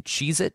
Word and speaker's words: Cheez-It: Apparently, you don't pronounce the S in Cheez-It.